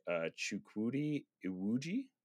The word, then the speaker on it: Chukwudi Iwuji: And then another look at the High Evolutionary, played by Chukwudi Iwuji. Is that how you say that? Max? – I'm so thrilled that you're hosting today. – This is so awesome. Any input on that, how I pronounce that, Max? – Hey, Max, can you pronounce that words for us, please? – Yeah, no, I think you're doing great. Keep it up.